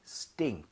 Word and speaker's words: stink: Stink. – 아! – 아하. – Sting, sting, stink.